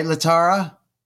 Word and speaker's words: Latara: Did I say that right, Latara? – Yes, you did.